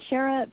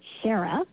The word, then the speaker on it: Sherra: And Shara, for people that are listening, is spelled S-H-E-R-R-A, even though it's said Shara. You know, like the singer.